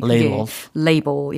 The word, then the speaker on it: label: 라벨이라고 많이 알고 있는 단어가 영어로는 label이라고 발음을 한다는 거예요.